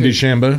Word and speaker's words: DeChambeau: Bryson DeChambeau.